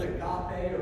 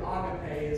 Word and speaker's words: agape: And we understand that the love of God is, if you've oftentimes heard it, the Greek word agape or agape is probably the better way to pronounce that word.